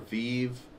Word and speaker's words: Vive: Is it Vive or Vive? – Everyone else pronounces it as Vive, so I'm gonna go with Vive.